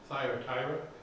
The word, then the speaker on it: Thyatira: Thyatira.